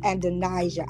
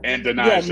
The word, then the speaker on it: Adonijah: And if I may, the correction, his name was Adonijah. I knew I was saying it wrong. – Adonijah, yeah, okay.